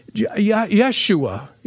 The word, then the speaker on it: Yeshua: Jesus, Yahweh, Yeshua is the Hebrew pronunciation.